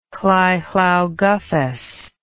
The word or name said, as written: Lleu Llaw Gyffes